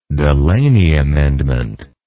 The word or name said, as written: delaney amendment